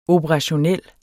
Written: operationel

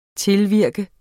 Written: tilvirke

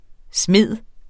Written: smide

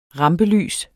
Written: rampelys

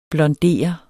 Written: blondere

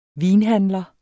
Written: vinhandler

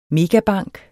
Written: megabank

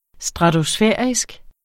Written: stratosfærisk

